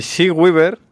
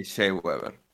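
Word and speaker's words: Shea Weber: Eh, She Weaver, eh, se pronuncia así, sí, Weaver. – Sí, She Weaver.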